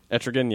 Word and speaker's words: Etrigan: Etrigan, yeah.